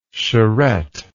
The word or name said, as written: charette